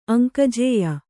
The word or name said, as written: ಅಂಕಜೇಯ